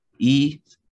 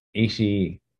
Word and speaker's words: Ishii: It's Ishii. – Ishii. Okay. – Basically, you got to say that there's two I's at the end.